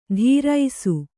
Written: ಧೀರೈಸು

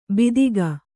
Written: ಬೀದಿಗ

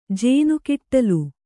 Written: ಜೇನುಕಿಟ್ಟಲು

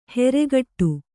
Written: ಹೆರೆಗಟ್ಟು

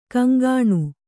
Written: ಘಿಂಘಿಣಿ

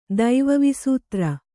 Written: ದೈವವಿಸೂತ್ರ

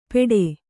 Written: ಪೇಠೆ

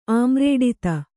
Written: ಆಮ್ರೇಡಿತ

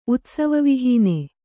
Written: ಉತ್ಸವವಿಹೀನ

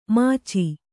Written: ಮಚ್ಚು

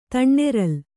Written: ತಣ್ಣೆರಲ್

